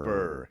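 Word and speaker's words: Burgh: But I'm still wrapping my head around the island's name, Burr. – Burr, yes. And it's B-U-R-G-H, and I had to learn to pronounce it properly. – Silence that G, huh? – You got to silence that G.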